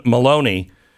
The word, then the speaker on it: Maloney: Rhymes with baloney. Carolyn Maloney.